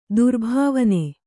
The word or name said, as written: ತ್ರಿಭುವನ